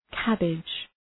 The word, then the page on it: cabbage: ‘kæbıdʒ